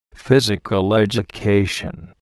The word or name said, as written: physical education